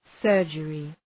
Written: surgery

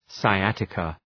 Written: sciatica

